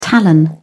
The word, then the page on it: talon: British English